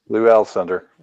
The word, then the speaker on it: Alcindor: How do you say that? Alcindor? – Yeah, Lou Alcindor.